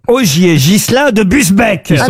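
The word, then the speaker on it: Busbecq: Augier Gilin de Busbeck, pardon, j'ai écorché son nom.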